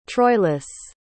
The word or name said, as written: troilus